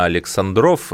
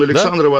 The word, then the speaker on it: Александров: Мне подсказывают, что ударение на Александров, это про человека, который был соавтором гимна. Ну, давайте признаем нашу ошибку. – Я не согласен абсолютно. Гимн Александрова, ансамбль Александрова, конечно.